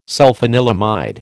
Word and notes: sulfanilamide: American English

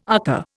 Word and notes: UCCA: Indian

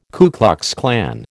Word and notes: Ku Klux Klan: American English

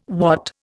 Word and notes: watt: Indian

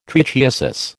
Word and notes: trichiasis: American English

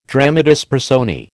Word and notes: dramatis personae: American English

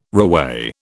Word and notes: reweigh: British English

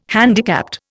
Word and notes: handicapped: Indian

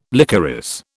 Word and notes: licorice: British English

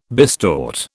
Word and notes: bistort: British English

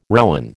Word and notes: rowen: American English

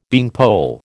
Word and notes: beanpole: American English

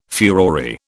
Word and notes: furore: British English

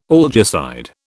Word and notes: algicide: British English

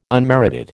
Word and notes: unmerited: American English

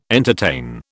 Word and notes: entertain: British English

